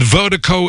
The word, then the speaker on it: Virdiko: And Vertigo is spelled V-I-R-D-I-K-O. That's V-I-R-D-I-K-O.